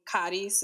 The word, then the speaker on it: Charis: It's the Greek, it's supposed to be charis is the actual pronunciation.